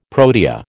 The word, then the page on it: protea: American English